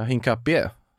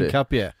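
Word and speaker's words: Hincapié: Ja, Hinkapi. – Hinkapi, alltså det är ju en accent över et. Ja, Hinkapie. – Ja, måste det bli. Hinkapie.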